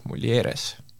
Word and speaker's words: Mulieres: täna on meil külas Merilin Rüütli ja tema tuleb meile Eesti ökopuhastusvahendite tootjast Mulieres. kas me hääldasime seda õigesti? – ja hääldasite, tere!